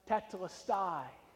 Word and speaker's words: tetelestai: It's pronounced tetelestai.